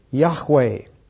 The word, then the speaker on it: Yahweh: And in the Hebrew, since they don't have vowels, his name is made up of four consonants, J-H-V-H, from which the word Jehovah has been coined. But that's a mistake because J is pronounced like a Y and V is pronounced like a W. So in English pronunciation, it would be Y-H-W-H, from which we get the word Yahweh.